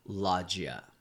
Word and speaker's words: Loggia: Well, one of the people that I'm very, very excited to talk about is this actor, Robert Loggia. I think that's how you pronounce his name. – Nailed it.